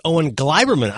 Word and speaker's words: Owen Gleiberman: She said his name is pronounced Owen Gleiberman. I go, you're kidding. That's what he says. Now, Owen did not correct us, so my apologies if he's listening or anyone else is listening. I would have assumed Owen Gleiberman the way it's spelled, but apparently it is Gleiberman.